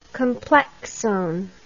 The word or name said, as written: complexone